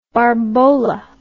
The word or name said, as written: barbola